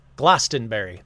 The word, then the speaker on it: Glastonbury: In fact, the town of Glastonbury, let's see how to pronounce that. I don't want to say everything wrong today. – Glastonbury. – Okay, fine.